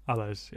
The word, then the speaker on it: Alles: Alles, yeah. I believe so. That's how he sings it.